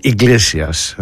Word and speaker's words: Iglesias: Ο Enrico Iglesias, έτσι τον προφέρουν, Iglesias,